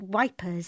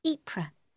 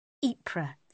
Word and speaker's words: Ypres: We've been having ambulances arrive all week from Waterloo with casualties from a place called Wipers in France. Chlorine gas, Celie. The Germans are using chlorine gas on our soldiers. It's pronounced Ypres. I've been reading about it in the papers. Ypres, then.